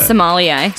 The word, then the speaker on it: sommelier: How do you pronounce it? – Sommelier. – Sommelier.